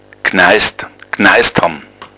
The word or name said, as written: gneisd